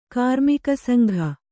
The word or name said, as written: ಕಾರ್ಮಿಕಸಂಘ